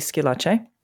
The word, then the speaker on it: Schillace: So my name is Brandi Schillace, and that is spelled S-C-H-I-L-L-A-C-E.